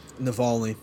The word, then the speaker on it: Navalny: Navalny.